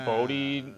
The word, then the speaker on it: Bote: Well, Bote rhymes with Grody, so, you know.